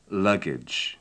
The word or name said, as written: luggage